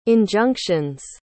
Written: injunctions

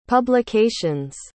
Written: publication's

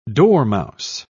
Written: dormouse

